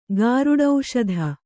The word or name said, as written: ಗಾರುಡೌಷಧ